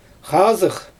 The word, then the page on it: xaazɯx: Xyzyl